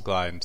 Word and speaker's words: gliadins: Gliadins, thank you.